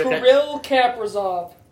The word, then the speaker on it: Kaprizov: Now, if I butcher this pronunciation, excuse me, I think you're going to have the same one here. – Kirill Kaprizov. – Yeah, that's actually pretty good.